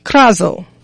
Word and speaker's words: krazo: So the Greek word for today, let me have it right here, is krazo. Krazo. That's spelled K-R-A-Z-O, and it comes out of Strong's G-2896.